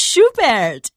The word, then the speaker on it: Schubert: and it's Schubert. Did I say it right? – It is Schubert, and yeah.